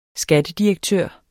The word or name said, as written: skattedirektør